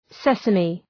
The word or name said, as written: sesame